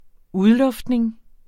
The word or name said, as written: udluftning